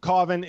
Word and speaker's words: Cauvin: Cauvin is like the allowable pronunciation. If you're going like the full, you know, my full name is Jean-Louis Cauvin.